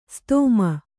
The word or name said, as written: ಸದುಮ